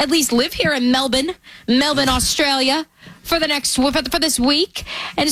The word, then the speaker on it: Melbourne: Apparently they don't pronounce the R. – Okay. So no R's. – No, Melbourne.